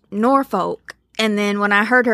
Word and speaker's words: Norfolk: Those that weren't born in the area will pronounce it Norfolk. Those who are from the area pronounce it Norfolk. And those who just wanted to get one over on the middle school teacher pronounce it Nafuk.